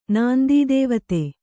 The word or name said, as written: ನಾಂದೀದೇವತೆ